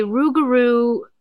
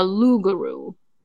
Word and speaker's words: rougarou: There is a rougarou folklore legend, and it's sometimes it's pronounced a lougarou, but it's a Cajun folklore.